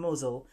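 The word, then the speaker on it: Mousehole: Mosel. That's how it's pronounced, but the spelling looks like mouse hole.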